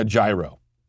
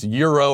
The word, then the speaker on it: gyro: Kind of very similar to when I go to get a gyro, I'm getting a gyro. I know it's, you could say it's pronounced euro or whatever. G Y R O.